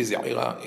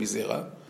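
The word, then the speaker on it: Zeira: His name was Rabbi Zaira in Bavel, but in Eretz Israel, people couldn't pronounce the Ayin, so they turned him into Rabbi Zera.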